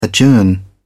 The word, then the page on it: adjourn: British English